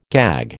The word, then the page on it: gag: American English